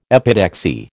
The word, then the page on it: epitaxy: American English